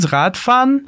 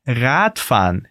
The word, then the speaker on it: Radfahren: Lass uns Radfahren. Andar de bike em alemão é Radfahren. Repete comigo focando na pronúncia do Rad. Rad. Vamos lá.